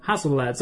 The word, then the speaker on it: Hasselblad: Is there an L in there? – There's an L in there. – Oh, yeah. Hasselblad. – Hasselblad.